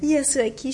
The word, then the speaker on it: Yasuaki: C'est la pièce euh, Eight Desires par le compositeur saxophoniste japonais Yazuki euh, Shimuyuki. Euh, est-ce que vous pouvez nous en... Oui, j'ai peut-être mal prononcé son nom. Est-ce que vous pouvez nous en dire un mot? Yasuaki Shimizu. Oui, ben en fait, c'est un, un jazzman japonais très très connu.